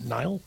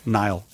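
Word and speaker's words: Niall: It's N-I-A-L-L. Is that pronounced Neil? I don't know. Nile? – Nile. – Probably. I think he's British. Nile. It's probably Nile.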